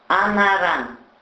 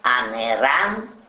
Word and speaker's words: анаран: Для сравнения, в приведенных примерах слова нельзя произносить как анами, анеран, анедян, эмами, эмеран и так далее.